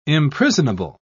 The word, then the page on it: imprisonable: British English